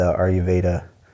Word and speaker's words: Ayurveda: Definitely want your input on this because I feel like gut health is a big part of the Ayurveda. Did I get that one right? – Yeah, you did say that correctly.